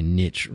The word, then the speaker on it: niche: Thanks for cutting in on my little intro there, and telling us how to pronounce niche or niche. – It's niche. – It's niche if you're in North America. G'day to all of our North American listeners. I had a business mentor that used to say niche rhymes with rich.